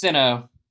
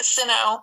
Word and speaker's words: Sinnoh: Like, he's gonna get an evolution with a Shinnoh stone or Shinnoh. – Shinnoh? I like Shinnoh. – Shinnoh stone. – I think it's Sinnoh. – How do you say it? Sinnoh.